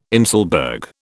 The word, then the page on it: inselberg: British English